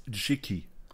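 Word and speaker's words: Jicky: Ähm, Aussprache übrigens Jiki.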